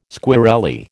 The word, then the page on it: squirrelly: American English